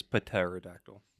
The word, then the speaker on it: pterodactyl: This is pterodactyl. – The P is silent.